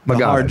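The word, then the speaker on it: Magaña: Angela Magana. – Magana, just exactly as it's spelled.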